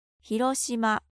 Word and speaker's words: Hiroshima: And a city you already know, but let's get the Japanese pronunciation. – Hiroshima.